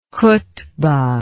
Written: khutbah

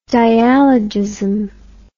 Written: dialogism

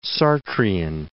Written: Sartrian

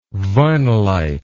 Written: vinylite

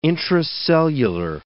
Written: intracellular

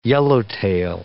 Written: yellowtail

